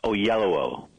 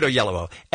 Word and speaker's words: Oyelowo: Oyelowo. – Okay, David Oyelowo.